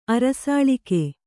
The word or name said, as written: ಅರಸಾಳಿಕೆ